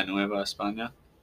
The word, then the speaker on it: Nueva España: He translated the name of the Mexican Empire to New Spain, or Nueva España. Did I say that right? – Yeah, Nueva España. That's a correct pronunciation.